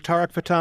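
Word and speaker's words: Tarek Fatah: We are talking with Tariq Fatah. Am I pronouncing that correctly, by the way? – Yes.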